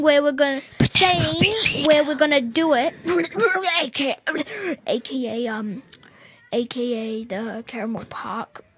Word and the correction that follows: Campbell Park: Campbell Park.